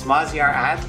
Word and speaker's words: Maziar Adl: His last name is A-D-L. So I guess that's more like Adl. Adl. Maz, can you pronounce it for us? – Yeah, it's Maziar Adl, actually.